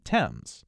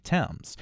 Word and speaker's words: Thames: spelled without the H in it, which would lead us to believe that it was always pronounced, at least somewhat, like Thames.